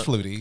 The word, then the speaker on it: Flutie: Which I didn't know how to say his last name. – It's Flutie.